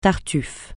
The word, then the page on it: tartuf(f): francais